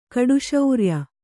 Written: ಕಡುಶೌರ್ಯ

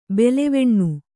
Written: ಬಾಲೆವೆಣ್ಣು

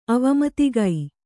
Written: ಅವಮತಿಗೈ

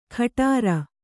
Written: ಖಟಾರ

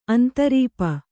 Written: ಅಂತರೀಪ

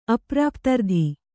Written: ಅಪ್ರಾಪ್ತರ್ಧಿ

